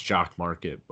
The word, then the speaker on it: Jock MKT: It's pronounced Jock Market, but you type Jock MKT onto the App Store.